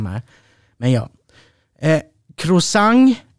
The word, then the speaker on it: croissant: Croissant. Croissant. Croissant. Ja, san. Det är bara ett n.